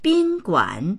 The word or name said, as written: bin1guan3